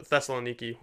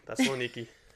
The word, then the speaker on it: Thessaloniki: or the Thessaloniki was like, and that's how you say it. I went to Greece once, Thessaloniki.